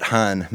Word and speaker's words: Hanh: If you're interested looking him up, it's spelled T-H-I-C-H, middle name Nhat, N-H-A-T, last name Han, H-A-N-H.